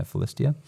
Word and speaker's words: Philistia: How do you say Philistine? Philistia? Yeah, Philistia.